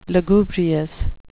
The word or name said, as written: lugubrious